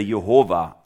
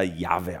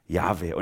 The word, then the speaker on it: Yahweh: Jahrhundert gab es dann ein paar ganz schlaue Bibelforscher, die haben angefangen zu rekonstruieren, wie könnte denn der ausgesprochen worden sein. Und so die ersten Phase in dieser Erforschung, da wurde das dann Jehova genannt. Wahrscheinlich wird er Jehova ausgesprochen. Und ein paar religiöse Gruppierungen sind da hängen geblieben im 18. Jahrhundert. Aber in der Weiterentwicklung ist man dann drauf gekommen, wahrscheinlich hat es sich eher Yahweh ausgesprochen oder Yahweh im Hebräischen dann.